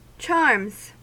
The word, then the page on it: charms: American English